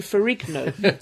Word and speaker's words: Ferrigno: Oh, I've always thought it's Ferrigno. – I bow to your proper Italian wisdom. – IGNO is the G is silent. It's like a N. – Yeah. – But as he lives in America, the G probably isn't silent.